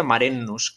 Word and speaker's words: Marinus: Se pronuncia Marenus, que siempre lo he pronunciado mal, hasta hace poco ah. que he descubierto que se llama Marenus en realidad, porque es holandés y tiene esa ah. pronunciación.